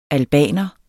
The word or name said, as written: albaner